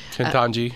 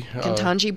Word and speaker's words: Ketanji: And that is, first of all, I actually don't know how to pronounce the Supreme Court nominee. Is it Ketanji? Is that right? – Ketanji. – Ketanji Brown Jackson.